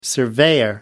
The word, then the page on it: surveyor: American English